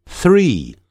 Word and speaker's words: three: 114 页，第七组 free 三或者三个 three 所以自由的念成 free 三个 three 你那个舌头一定要吐出来 three